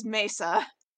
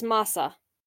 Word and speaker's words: Masa: M-A-S-A. Okay, let's Google this, okay? – Yeah, like, hold on, yeah, because, like, they say it in the dub as Mesa. – Yeah, it's Masa.